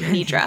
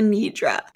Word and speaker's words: nidra: How do you say it? Nidrir. – Yoga, yoga nidra. Nidra. – Yeah, I have a lot of trouble pronouncing words. – Oh my God. – I don't want to go back to the Sister Schubert situation, but I did yoga nidir last week. Is that, wait, did I say that right? – It's yoga nidra.